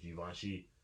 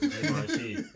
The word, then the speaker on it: Givenchy: the creative director for Alyx and Givenchy, um, are executive producing his album. That's how it's pronounced, guys. Just so you know. It, it, Givenchy.